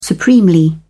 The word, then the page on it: supremely: British English